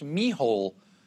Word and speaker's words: Micheál: Now, his name kind of looks like Michael, but it's pronounced Mihole.